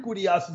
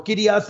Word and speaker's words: kurios: We recognize kurios is not always used for Yahweh, but there in the Gospel of St. Luke, chapter 1, every time kurios is used, or kurios, depending how you want to pronounce it, every time that Greek word for Lord is used, it is referring to Almighty God, to Yahweh, every time.